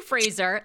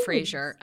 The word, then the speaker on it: Fraser: Well, it's actually, it's supposed to be Fraser, like Brendan Fraser. But I say it Fraser.